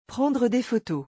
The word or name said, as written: prendre des photos